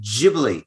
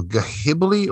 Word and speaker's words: Ghibli: So, if you say ghost, you don't pronounce the H in ghost, but this one, it's got G-H-I-B-L-I. So Ghibli, or do you say Ghibli, or I don't Ghibli understand what you're saying.